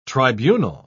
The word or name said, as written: tribunal